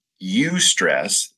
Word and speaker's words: eustress: So it's important to have eustress, which is EU stress. That's the way that's spelled and pronounced, is EU stress, eustress.